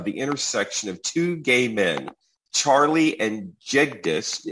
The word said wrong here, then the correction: Jignesh: Am I saying that right? – Jignesh. – Well, that sounds like there's an N in it. Is that how it's pronounced? – Yeah, Jignesh. You got it with a D? – Oh, yes. Okay. Yes, there is an end in it.